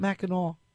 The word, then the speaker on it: Mackinac: And kind of fun to think about going up from where Huron House B&B is located, because to get to Mackinac Island, which is spelled Mackinac, but you pronounce it Mackinac, you have to leave your car and either fly or take a boat.